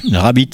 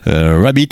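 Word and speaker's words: Rabbeat: Rabbit, ça s'écrit presque comme un lapin, mais on remplace le i par ea comme bit quoi. Rabbit, voilà, c'est la première sortie, je crois, du label de Manuel Malin, le label mécanique, enfin MKNK.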